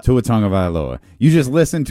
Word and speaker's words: Tua Tagovailoa: Listen to him say it, Tua Tagovailoa, and then write it out your own way. – Yeah. – Tua Tagovailoa.